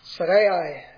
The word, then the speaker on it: Sarai: Sarai, as the word should be pronounced here, Abraham's wife, bare him no children.